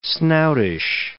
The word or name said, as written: snoutish